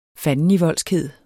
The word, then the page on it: fandenivoldskhed: [ ˈfanəniˌvʌlˀsgˌheðˀ ]; [ ˈfanəniˈvʌlˀsgˌheðˀ ]